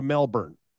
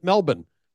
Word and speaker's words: Melbourne: It's a long way all the way to Melbourne. And I, of course, learned how to pronounce it Melbourne, not Melbourne, as we would say in America.